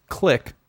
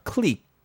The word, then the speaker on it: clique: I think one of his people wrote that because he mispronounced click as in a group of people. – Yeah. – Clique.